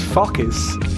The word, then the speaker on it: Fokis: That's Focus. F-O-K-I-S. Careful how you say it.